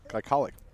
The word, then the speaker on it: glycolic: And the word was glycolic. Glycolic.